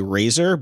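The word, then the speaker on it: RAISR: It's they're calling it I think the pronunciation would be Razor, but it's RASIR, rapid and accurate super image resolution, because it's super.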